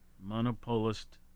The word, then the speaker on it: monopolist: Monopolistic? – Well, there's no IC. Monopolist corporation.